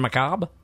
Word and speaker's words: macabre: Mostly I've heard it pronounced macabre without the R-E. They're silent. And that's the way I like best. – Well, I think that I like macabre. – Well, then you say it that way.